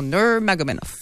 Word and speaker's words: Nurmagomedov: Puis si tu insistes sur la première syllabe, ça te donne un peu plus de temps pour te concentrer sur le reste du nom. Nurmagomedov.